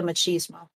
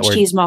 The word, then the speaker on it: machismo: How do you say that machismo. – Word? Machismo.